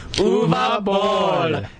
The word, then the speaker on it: Uwe Boll: Uwe Boll.